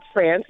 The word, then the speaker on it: France: So, yep, it's France. You said it right, just like the country.